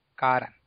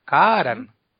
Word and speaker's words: karan: Y también se alarga la vocal de la raíz. Si la raíz es kar, pues se le pone una tilde, lo que quiere decir que se pronuncia más larga. O sea, karan.